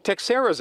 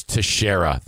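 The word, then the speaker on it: Teixeira: It's Texera. It looks like T E X I E R A. I believe that's pronounced Texera just because there was a baseball player named Texera.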